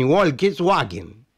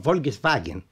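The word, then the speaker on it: Volkswagen: وحتى نطق الاسم يختلف عن الشائع عربيا، فحرف W في الألمانية ينطق V، كما في فوكس واجن يكتب W يعني فولكس واجن، لكن يلفظ فولكس فاجن،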